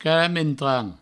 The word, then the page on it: caramentrant: provençal